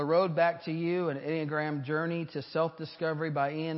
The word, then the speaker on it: Enneagram: See, if you pronounce the E and the A, it's Enneagram is what it is.